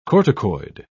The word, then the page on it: corticoid: British English